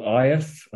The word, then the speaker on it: Aed: He had his father, who's called, I think it's called Aeth. I think that's how you pronounce it, or spelled A-E-D, but I think pronounced Aeth.